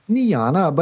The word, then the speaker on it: Niejna: So we went for the next best thing, N-I-E-J-N-A, which is how you pronounce Niana in Flemish.